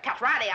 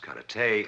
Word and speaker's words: karate: I'm gonna practice the art of cat-roddy on you. – Your mistake. Cat-roddy, as you call it, correctly pronounced karate, is a defensive form.